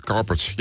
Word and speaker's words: Inspiral Carpets: Sinds de Inspiral Carpets. Zeg ik het goed? Carpets, ja.